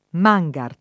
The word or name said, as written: Mangart